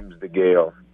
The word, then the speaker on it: DeGale: Is it DeGale? Am I saying that right? – James DeGale.